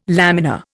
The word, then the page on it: lamina: Indian